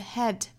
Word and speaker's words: had: 首先我们来做英音的语音讲解 Look, if you had one shot 我们看这个 had 尾部的这个 d 的发音即使在歌里它的语速比较慢的情况之下这个 had 也没有发出来